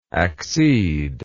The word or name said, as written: acceder